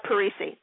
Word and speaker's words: Parisi: Rob Parisi, that's the correct pronunciation of his name. I should know better because that's an Italian last name and I'm from Philadelphia. I should know that better.